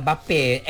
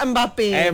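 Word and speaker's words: Mbappé: Macam Bape, Mbappe tu. Dia Mbappe. Ada titik di atas. Saya faham kan. – Biasa kita tengok M Star kan. – Contoh. – Jadi dalam bola Mbappe.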